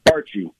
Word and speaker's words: Bartsche: Bartsche.